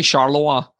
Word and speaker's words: Charleroi: Andy, it's definitely Charleroi is the because before we get pulled up for our incorrect pronunciations, it's definitely like it has to be.